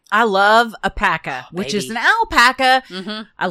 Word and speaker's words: Paka: It's called Pekka. It's spelled P-A-K-A.